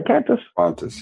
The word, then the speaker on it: Qantas: Actually, like this week, I saw an article that kind of opened a lot of people's eyes, and it was from Qantas. Did I say that right? Qantas, yeah.